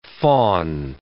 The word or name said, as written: fawn